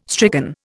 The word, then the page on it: stricken: Indian